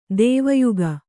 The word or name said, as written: ದೇವಯುಗ